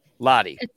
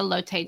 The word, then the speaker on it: Lotte: Even spent a year in the KBO playing with Lotta. Am I saying that right? – Lottie. Yes. It's probably more like the Lotte Giants.